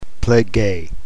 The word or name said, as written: plege